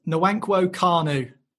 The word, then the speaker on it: Nwankwo Kanu: That was Nwankwo Kanu. – And perfectly pronounced as well. I can't give you a bonus point, but perfectly pronounced.